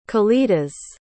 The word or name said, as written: kalidas